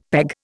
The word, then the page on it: peg: Indian